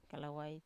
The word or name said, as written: kalawai